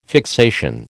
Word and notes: fixation: American English

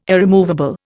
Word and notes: irremovable: Indian

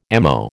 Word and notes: ammo: American English

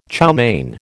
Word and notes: chow mein: American English

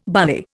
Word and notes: barley: Indian

